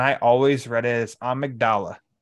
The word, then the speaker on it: Amygdala: How do you pronounce his name? – When you it say it out loud. Amygdala. – Amygdala? Okay.